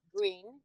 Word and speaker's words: Grin: So Green is spelled GRIN. Green, yes.